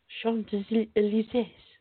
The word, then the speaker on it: Champs-Élysées: Champs-Élysées.